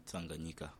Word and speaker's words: Tanganyika: He governed Tanganyika. Is that how you pronounce it? – Tanganyika.